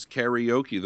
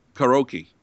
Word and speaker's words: karaoke: And how is that supposed to be pronounced? Because I've never heard it any other way. – Karaoke. – Oh, is that a Japanese? – Yeah, it's karaoke. It means empty orchestra. And the Japanese pronunciation is karaoke, which Americanized should be karaoke, but not karaoke. There's no E in the middle of that word.